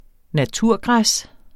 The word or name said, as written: naturgræs